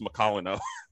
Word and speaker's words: Macolino: That's the only time I've heard it pronounced Macolino.